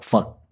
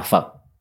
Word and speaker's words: Phật: The word for Buddha in Vietnamese sounds a lot like a bad word in English. – Yes, it's spelled P-H-A-T, and it's Sino-Vietnamese. Fuck, yeah, it sounds like not a nice word in English language.